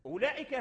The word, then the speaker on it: أولئك: التوسط لا تظن أن هذا المد بسبب الواو ديال أولئك، لا، أولئك هذا الواو محذوف في اللفظ لا تلفظ به، لكنه هو ثابت في الرسم.